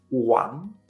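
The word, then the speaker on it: wang (王): Hemos dicho que rey se escribe con W, pero para nosotros es como si fuera una U. Así que rey en chino se dice wang, wang, en segundo tono. Y el verbo querer o tener que se escribe Y-A-O, pero para nosotros es como si fuera una I latina, así que es yao.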